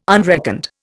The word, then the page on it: unreckoned: Indian